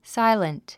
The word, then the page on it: silent: sáilənt; サイレント